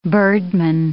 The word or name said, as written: birdman